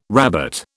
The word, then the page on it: rabbet: British English